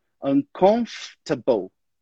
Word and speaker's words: uncomfortable: Uncomfortable. – Good. Essa aí é meio tricky mesmo, essa palavra. A gente, na verdade, não pronuncia aquele O e o R. Então, a gente fala uncomfortable.